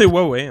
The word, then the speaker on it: Huawei: On peut aussi citer le, constructeur, pardon, Huawei ou Huawei, je crois qu'il faut prononcer. Euh, qui est non, non, c'est Huawei. Hein. C'est ouais, je crois que c'est un peu trop français ça.